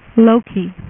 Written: Loki

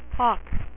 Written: Hawk

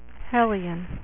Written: Hellions

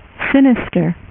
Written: Mister Sinister

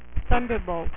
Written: Thunderbolts

